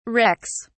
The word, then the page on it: rex: British English